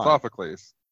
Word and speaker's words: Sophocles: Sophocles. Okay. Hey, guys. Sophocles. Come on. – Sophocles.